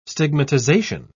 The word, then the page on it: stigmatization: British English